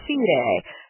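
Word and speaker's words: scire: Its root is the Latin stylus, meaning knowing, and that itself comes from the verb sine. That's how I'm going to pronounce it, which means to know. So when you think about that, it's spelled S-C-I-R-E, C-A.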